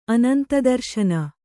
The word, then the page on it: ಅನಂತದರ್ಶನ: anantadarśana